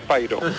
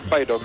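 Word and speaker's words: Pyro: Se dice Piro, es de Pairo. Aquí le llaman Pairo, mis compañeros. – Sí, debería ser Pairo, ¿no? Lo que pasa es que en España, pues parece que pronunciar Piro es más fácil que pronunciar Pairo.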